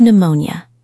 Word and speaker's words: pneumonia: Think of pneumonia. It has a silent P.